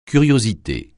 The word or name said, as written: curiosité